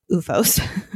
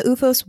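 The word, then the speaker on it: OOFOS: And so I bought UFOs. – How do you spell it again? – Okay, so it's O-O-F-O-S.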